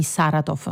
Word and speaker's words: Saratov: Allora io partirei con te dalle ultime notizie con gli attacchi nella notte su Kiev, ma anche sulla città russa di Saratov.